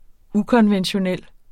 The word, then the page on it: ukonventionel: ˈukʌnvənɕoˌnεlˀ